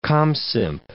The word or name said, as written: comsymp